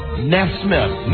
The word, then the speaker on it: Nesmith: You both had it wrong. You have to pronounce the names correctly. – Michael Nesmith. – Nesmith. There you go. It's an S and a myth.